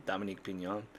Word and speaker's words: Dominique Pignon: and it's Dominic Pignon. I just wanted to make sure I'm getting his name right. Yeah, Dominique Pignon.